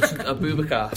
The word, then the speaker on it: Aboubakar: Aboubakar.